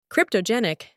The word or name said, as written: cryptogenic